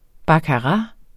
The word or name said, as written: baccarat